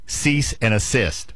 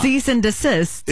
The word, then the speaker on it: cease and desist: I always thought it was cease and assist. It's not. – Cease and desist. – It's desist. Yes. It's not assist.